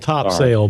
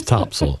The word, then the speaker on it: Topsail: It's spelled Topsail, but if you're from around here, it's Topsail. Yeah.